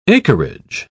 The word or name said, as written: acreage